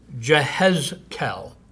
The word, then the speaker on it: Jehezkel: The English-speaking people had been calling him Ezekiel for some time now, but actually the Hebrew is Jehezkel. So if we were being accurate in our pronunciation, that's what we would say.